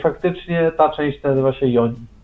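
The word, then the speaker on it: yoni: Tak, ale czyta się przez J bodajże. Joni. – Joni.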